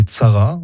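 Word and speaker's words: Tsara (צרה): Si on prend le mot hébreu ancien Sara, prononcé Tsara en hébreu moderne, qui veut dire le malheur, celui-ci était utilisé très couramment chez les Juifs du Maroc.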